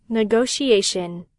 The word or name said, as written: negotiation